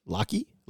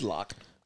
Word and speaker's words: Locke: Locky? Locke? – This is Locke. – It's a silent E, people.